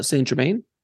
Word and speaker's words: Saint-Germain: So you started off channeling Tobias, but now you channel Saint Germain? – Well, yeah, it's Saint Germain, but he goes by Adamus, Saint Germain. Okay. Or if I say it correctly, Adamus Saint-Germain. That's the French pronunciation.